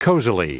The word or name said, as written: cozily